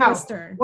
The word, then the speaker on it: Worcester: It has Worcester, and people Worcester. Cannot get there. – When like I was Worcester, a kid, I lived in Worcester.